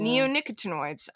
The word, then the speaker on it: neonicotinoids: they're called neonicotinoids. I'm probably pronouncing that wrong. – Neonicotinoids. – But neonicotinoids.